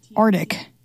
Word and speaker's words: Arctic: Oh, apparently dropping the C is the way that you say it. The first or the second? The first C. So it's like Arctic.